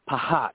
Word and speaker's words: phat: But then I looked it up, and PHAT is a Sanskrit word as well. It is pronounced pahat, and it's an expression like it's crack.